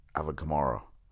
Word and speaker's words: Kamara: Wait, is it Alvin? I always get his name wrong. Alvin, is it Kamara? Alvin Kamara.